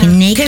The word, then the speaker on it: Knekki: El delantero noruego es accionista de, disculpa, no sé cómo se pronuncia, algo como Neki. Es que es K-N-E-K-K-I. – Dilo como Neki, quieras, amiga. – Neki, Neki.